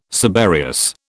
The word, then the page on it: subereous: British English